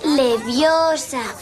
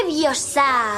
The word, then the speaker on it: Leviosa: Además, lo estás diciendo mal. Es Leviosa, no Leviosa. – Intenta hacerlo tú si eres tan lista. Anda, venga. – Wingardium Leviosa.